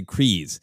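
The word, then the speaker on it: Kryze: because I think in the episode they say Kreese. – Yeah. – But there's something about that Y that makes me feel like I can't say Kreese.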